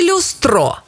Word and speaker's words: люстро: Люстро чи люстро? Як правильно? Правильно, люстро. Наголос на «ю».